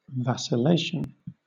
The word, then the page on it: vacillation: British English